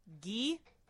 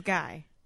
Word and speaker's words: Guy: No, is it pronounced Gee? – No. – Oh, because hockey players are pronounced, when it's spelled like that, it's pronounced Gee. – But it is Guy. – It's Guy.